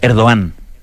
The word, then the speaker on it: Erdogan: Que me dijeron, oye, me, dijeron cómo se dice. Erdogan. Sí, Erdogan.